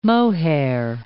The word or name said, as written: mohair